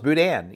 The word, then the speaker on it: boudin: Both of you don't say boudin with a hard N at the end. – No, it's Dan. Like, you know, we don't correct you because we're Cajuns. You're endeared to us because you mispronounce it. We love that. – Well, we have boudin up here in Iowa now. – That was very close. Boudin. Yeah, boudin. It's an eh.